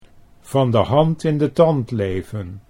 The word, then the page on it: van de hand in de tand: Dutch